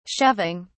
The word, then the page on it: shoving: British English